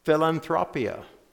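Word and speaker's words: philanthropia (φιλανθρωπία): The Greek word translated here is actually a compound word, and it is pronounced philanthropia, from which we get philanthropy or philanthropic.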